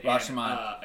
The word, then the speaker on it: Rashomon: but it's inspired by Akira Kurosawa's Rashomon. – Yes. – I hope I pronounced that correctly. – Yes, you did. Rashomon.